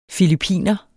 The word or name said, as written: filippiner